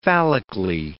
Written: phallically

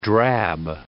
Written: drab

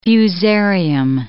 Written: fusarium

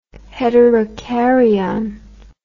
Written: heterocaryon

